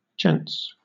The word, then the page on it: gents: British English